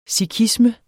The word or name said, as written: sikhisme